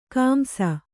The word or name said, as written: ಕಂಸ